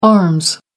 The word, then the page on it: arms: American English